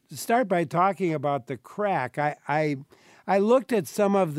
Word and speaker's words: craic: And maybe you should mention first what the title means, The Crack. And it's spelled C-R-A-I-C, which I think is Gaelic.